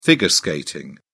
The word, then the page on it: figure skating: British English